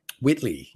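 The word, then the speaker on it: Whiteley: Um, so I, I think you know, like what you're learning to as well is this line that I think uh, Rosie Huntington Wheatley, Wheatley, uh, I'm gonna, I, I butchered that. I Wheatley, Whitley, um, Whitley, yeah.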